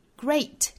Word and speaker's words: great: Great，首先注意前面这个元音呢是双元音的 a 的发音，所以是 great，跟昨天我们讲到的这个 waste 是一个元音哈，所以 great waste great。